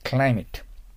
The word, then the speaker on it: climate: さあ、その次、クリマテと書いてありますけど、発音気をつけないといけませんね。はい。 Climate。 Climate。日本の Climate は稲作に適している。